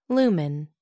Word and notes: lumen: American English

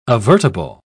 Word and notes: avertible: British English

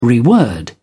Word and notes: reword: British English

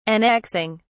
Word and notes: annexing: British English

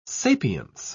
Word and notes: sapience: British English

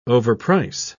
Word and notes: overprice: British English

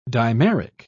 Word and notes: dimeric: British English

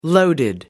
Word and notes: loaded: American English

